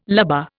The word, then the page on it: lubber: Indian